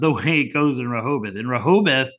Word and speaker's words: Rehoboth: Rehoboth. Did I say that correctly? – Yep.